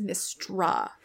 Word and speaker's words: Mystra: Um, and when Karsus does this thing, I'm pretty sure she dies, but she immediately comes back as Mystra. Oh, that's um, cheating. You you said Mistral earlier. I'm like, have I been mispronouncing that name this whole time?